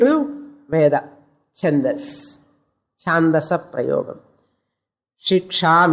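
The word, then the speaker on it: Shiksha (शिक्षा): Sikshyate anaya iti Shiksha. Actually, the right form is Shiksha, short to Shakara. She. It is not long, but in the book, Shiksha is there.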